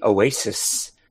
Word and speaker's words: OASYS: So there is this new device called the Oasis, which is spelled OASYS.